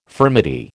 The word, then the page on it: furmety: American English